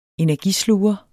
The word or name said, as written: energisluger